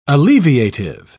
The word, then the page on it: alleviative: British English